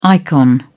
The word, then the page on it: icon: British English